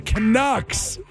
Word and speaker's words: Canucks: Oh, the Canucks.